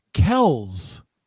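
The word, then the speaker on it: Kelce: According to Babbel, it's pronounced Kells.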